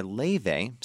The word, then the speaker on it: Laufey: Just now, that was the Icelandic Chinese singer Laufey, spelled L-A-U-F-E-Y, performing her original song called Like the Movies.